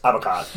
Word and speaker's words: avocat: Well, it's probably spelled avocado cat. Yeah. And you only pronounce avocat. – Yeah. Yeah, yeah. Because we drop 90% of the letters in any French word.